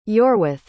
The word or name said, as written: iorweth